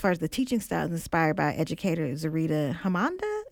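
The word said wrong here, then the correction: Zaretta Hammond: Is that how you pronounce it? – Zarita Hammond, yeah. – Hammond.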